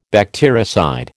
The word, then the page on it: bactericide: American English